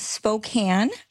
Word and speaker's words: Spokane: We've reached her at home in Spokane, I think is how you say it, Washington. Hello. – Hello. Can you hear me okay? – I sure can. And am I saying that correctly? Where are you? What is your town? – It is Spokane, okay. Washington. So close.